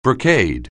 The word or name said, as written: brocade